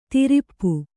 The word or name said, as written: ದುರಾಪ